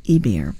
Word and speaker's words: Ibert: That's a classic Columbia recording from 1960, re-released on the Sony label, of Escale, Ports of Call, by the French composer Jacques Ibert. Ibert is spelled I, B as in boy, E-R-T as in Tom, in case you're looking for his music.